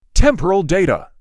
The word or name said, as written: temporal data